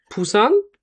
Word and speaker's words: Busan: Und die ersten Flüge gingen nach Busan. Richtig ausgesprochen? – Ja, es wird zwar mit B geschrieben, aber wird P ausgesprochen.